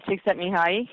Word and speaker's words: Csikszentmihalyi: I really looked in a lot to, Martin Seligman and I can never pronounce his name, but Csikszentmihalyi, the guy that talks about flow. – Yeah, Mihai, Csikszentmihalyi.